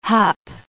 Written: hup

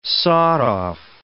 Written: sawed-off

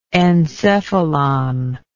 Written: encephalous

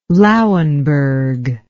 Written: Lauenburg

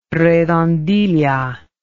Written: redondilla

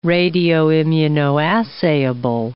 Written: radioimmunoassayable

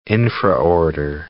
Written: infraorder

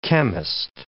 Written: chemist

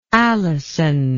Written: alison

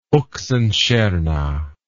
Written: Oxenstierna

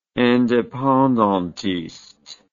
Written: independantiste

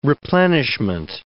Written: replenishment

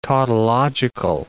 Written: tautological

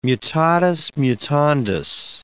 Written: mutatis mutandis

